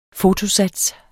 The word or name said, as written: FOTOSATS